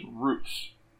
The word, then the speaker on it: Ruth: Because you can hear that, like, okay, you can hear clearly that, like that Chisei pronounces his name as Roots. But for some reason, they spell his name in the subtitles Ruth.